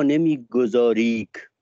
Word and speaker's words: نمیگذاریک: شبیه میگم تو یکی از بیتاش میگه که ای دل تو مرا نمیگذاریک بنشینم و صبر پیش گیرم. این نمیگذاریک یعنی نمیگذاریکه، با تلفظ نمیگذاریک قافیه کرده با مثلا تاریک و تاجیک و نزدیک و اینا.